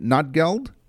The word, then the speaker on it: Notgeld: The form of currency was called Notgeld, N-O-T-G-E-L-D. Did I say that right? Notgeld? – Yeah, Notgeld.